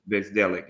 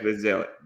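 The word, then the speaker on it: Bzdelik: Bezdelic. – Yeah. Brought in Jeff Bezdelic because he knew that, like, that man knew how to coach defense.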